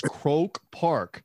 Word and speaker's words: Croke Park: The name is Croke Park, not Croke like you're dead. So it's interesting. It's a name, Croke, C-R-O-K-E, like Coca-Cola is Coke for us, but it's pronounced Croke Park.